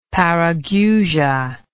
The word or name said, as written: parageusia